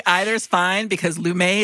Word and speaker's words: Lumet: So it's pronounced Lumet. I didn't know that. – I think so. Anytime we've said it on TCM, I feel like we've said Sidney Lumet.